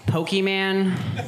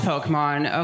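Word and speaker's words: Pokémon: there are still so many people that are pronouncing it Pokemon. Like, we all know it's pronounced Pokemon, okay?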